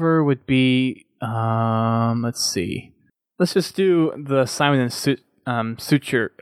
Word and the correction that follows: Schuster: How do you say that? Schuster. Schuster, thank you.